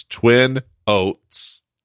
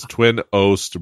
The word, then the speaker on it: Twin Oast: It's Twin Oast, OAST. It's not oats. I'm not saying it incorrectly. It is Twin Oast Brewing, and they're based out of Clinton, Ohio, Port Clinton, Ohio.